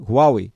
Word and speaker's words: Huawei: Huawei, falei certo? Huawei, ela isso.